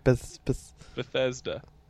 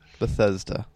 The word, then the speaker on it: Bethesda: Bethesda. – Bethesda.